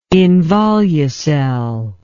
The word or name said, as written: involucel